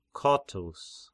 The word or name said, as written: Kotys